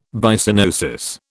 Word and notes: Byssinosis: British English

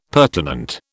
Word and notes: pertinent: British English

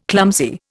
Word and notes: clumsy: Indian

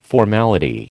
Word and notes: formality: American English

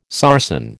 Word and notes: sarsen: American English

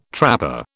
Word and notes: trapper: British English

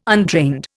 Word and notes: untrained: Indian

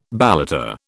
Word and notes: balata: British English

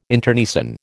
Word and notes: internecine: American English

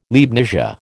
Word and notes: Leibnizia: American English

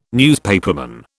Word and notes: newspaperman: British English

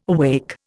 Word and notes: awake: Indian

